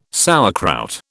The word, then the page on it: sauerkraut: British English